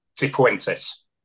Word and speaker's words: Cifuentes: And for people like me who struggle with pronunciation, and you're a teacher, what is the correct pronunciation of his Cifuentes. – Name? – Cifuentes. – Say that again? – Cifuentes.